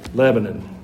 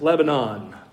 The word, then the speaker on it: Lebanon: Now, I think it's the cedars of Lebanon, but we're in Middle Tennessee, so it's pronounced Lebanon. I had to get used to that when I moved here. Growing up in Georgia, it was Lebanon. And I'm pretty sure in the Middle East, it's Lebanon.